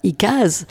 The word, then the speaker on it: ICAD: Donc euh, ça s'écrit ICAD, ça, ici on prononce ça ICAS, donc est une association.